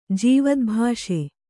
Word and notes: ಜೀವದ್ಭಾಷೆ: Kannada

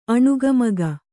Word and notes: ಅನೇಕಮುಖ: anēka mukha

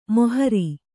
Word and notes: ಮೋಹರಿ: Kannada

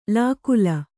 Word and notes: ಲಕ್ಕಲಿ: Kannada

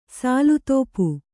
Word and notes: ಸಾಲುತೋಪು: sālu tōpu

